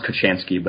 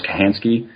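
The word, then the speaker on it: Kochanski: And then another book actually is Bushcraft by, it sounds Kuchansky, but he pronounces it Morris Kahansky.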